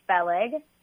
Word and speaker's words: Feleg: So my real name is Feleg, and it's spelled F-E-L-E-G, and my last name is Abraham.